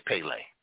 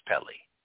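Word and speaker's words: Pelé: Us Americans say Pele. It's Pele.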